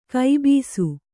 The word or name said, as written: ಕಬ್ಬಸು